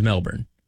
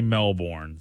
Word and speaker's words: Melbourne: Locals do not say Melbourne. So I think they do say Melbourne. – Okay. – So it's kind of like not pronouncing the T's in Atlanta. – Yeah. So the R is silent.